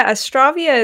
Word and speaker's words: Astravia: Astravia is actually the right way to say it. I say Astravia a lot, but Astravia is kind of, I'd say the canonical way to pronounce it, because it kind of comes from astra, like astral, like stars, you know.